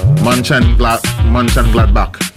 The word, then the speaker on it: Mönchengladbach: Are they Borussia Mönchengladbach? Yeah. Mönchengladbach.